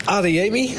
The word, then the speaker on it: Adeyemi: Adeyemi, zeg ik het goed? – Ja.